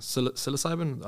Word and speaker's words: psilocybin: Tell me about I saw on your Instagram, you talk about psilocybin.